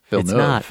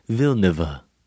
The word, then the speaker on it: Villeneuve: I'm hoping it's Villeneuve. – It's not. It's Denis Villeneuve. – That's where I probably got it. – It adds a third syllable.